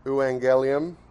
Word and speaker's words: Evangelium: and so that gets turned into Evangelium, because the V is pronounced as a W in Latin, and then it translates into German, and in German they actually would have the Evangelium is how you'd see it written.